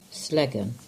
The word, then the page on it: Sellegan: English